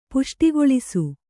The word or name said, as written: ಪುಷ್ಟಿಗೊಳಿಸು